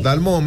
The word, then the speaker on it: Dalmau: o Dalmau, ¿cómo le digo? ¿Dalmó o Dalmau? Se escribe Dalmau. – Dalmó, más fácil. – Dalmó. Flavia Dalmó, como Bianchi.